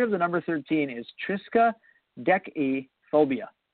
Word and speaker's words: triskaidekaphobia: So the first one is pronounced triskaidekaphobia.